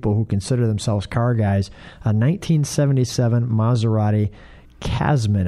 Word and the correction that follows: Khamsin: If I'm saying it wrong, somebody will correct me. It's Khamsin.